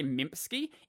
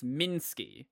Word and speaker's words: Minsky: I said that name wrong every single time. I always try to say Mimpsky. It's Minsky, I think. Pretty sure it's Minsky.